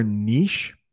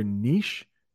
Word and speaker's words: niche: That word there, niche, it actually has two pronunciations. You'll hear niche and you'll hear niche. Okay. Those both work.